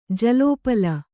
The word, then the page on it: ಜಲೋಪಲ: jalōpala